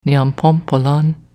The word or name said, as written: Niapompolan